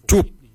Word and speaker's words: djob: Quart is spelled G-O-O-R-D. Quart. Quart is your heart. For kidney, we say jup. Jup is spelled D-J-O-B.